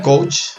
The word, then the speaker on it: coach: La palabra coach, pronunciada así coach, es el profesional que utiliza el coaching, pronunciado como coaching, el coach, dos coach.